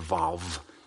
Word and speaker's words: vav: And it's a letter W, a wow, or a vav, depending which way you want to pronounce it.